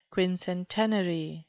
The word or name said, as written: quincentenary